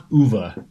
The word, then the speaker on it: Ove: Oh, I think it's pronounced Uva because it's Swedish.